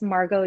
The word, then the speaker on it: Margo: You can pretty much find me anything that's Margo Yoga.